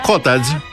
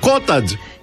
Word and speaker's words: cottage: Για να φανταστείτε ότι το cottage το λέμε cottage. Ρε cottage. – Είναι δυνατόν να αλλάξω εγώ τον τρόπο που λέω cottage cheese? Cottage cheese δεν είναι cottage. Μπορεί εδώ στην Ελλάδα να το λέτε cottage και καταλαβαίνω γιατί το λέτε. Γιατί έτσι μάθατε να προφέρετε το O.